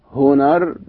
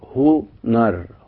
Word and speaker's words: هنر: إن بدأ بها الكلام، يعني إن كانت الهاء في أول الكلام زي كلمة مثلا هنر، هنر بمعنى الفضل، هنر هنر، الهاء نطقت لأنها في بداية الكلام، هنر بمعنى الفضل.